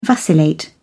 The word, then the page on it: vacillate: British English